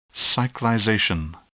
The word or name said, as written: cyclization